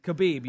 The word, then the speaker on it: Khabib: Now, Khabib, am I saying it right? – Khabib, yeah.